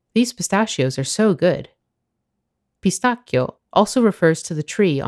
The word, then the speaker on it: pistacchio: In Italian, it's just three syllables. – Pistacchio.